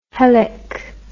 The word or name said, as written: helic